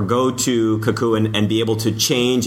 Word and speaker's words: Cacoo: I believe it's pronounced Kakoo, C-A-C-O-O, and this is an online diagramming tool where you can create really simple diagrams.